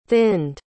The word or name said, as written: thinned